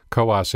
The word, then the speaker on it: Coos: Coos is pronounced in two syllables, and any candidate who does not know this fact risks being banished to Oregon.